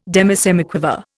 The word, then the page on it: demisemiquaver: Indian